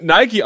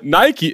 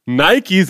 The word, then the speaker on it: Nike: Und da steht drauf, ja, Nike, wird Nike ausgesprochen. Mir ist durchaus bewusst, dass der offizielle Name Nike ist. Aber niemand, der mir das schickt, auch ihr, kann behaupten, dass er im Alltagsgebrauch Nike sagt.